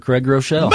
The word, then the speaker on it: Groeschel: Will you please tell us the correct pronunciation of Pastor Groeschel? – Pastor Craig Groeschel. – Boom! – You got it. – You nailed it.